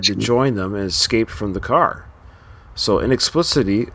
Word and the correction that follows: inexplicably: uh, uh, sorry, so inexplicably, how do you say that word? – Inexplicably.